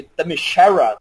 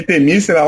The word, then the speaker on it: Themyscira: Que eu sempre falei Themyscira, falo esse nome aí, Themyscira, eu fiquei, caralho, cara, eu falei errado a minha vida inteira essa porra. – Detalhe, não é Themyscira, eu errei, é Themyscira, na verdade.